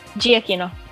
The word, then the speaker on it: Giacchino: You know, I hear about like people like Michael Giacchino. I don't know how to say his last name. – Giacchino.